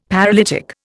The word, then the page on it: paralytic: Indian